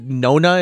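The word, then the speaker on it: Nonna: Is it Nona? Is that how you pronounce that? It's like the Italian word for grandmother, the Nona.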